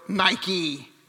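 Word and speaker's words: Nike (νίκη): Modern day, we say Nike or Nike, right? Nike, according to the internet, is the correct way to say it.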